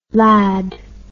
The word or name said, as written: lande